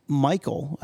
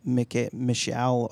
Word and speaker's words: Michal: I love in 1 Samuel 18, he talks, he says, who am I that I would even have the ability to marry Michal, who is Michal, Michelle, I don't know how her name is spelled, but Saul's daughter. – Right. – And I think it's Michal, M-I-C-H-A-L.